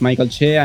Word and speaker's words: Mike Alche: My name is Mike Alche.